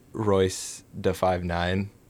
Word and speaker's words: Royce da 5'9: And Royce da 5'9".